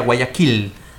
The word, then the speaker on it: Guayaquil: E em espanhol é Guayaquil? É, é, uma palavra indígena, né? – Então a pronúncia seria Guayaquil.